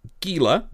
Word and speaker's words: Gila: Rob Vincent says Rob and Gila. Right? Am I reading that right? Rhymes with Sheila. Or Leah. Yes.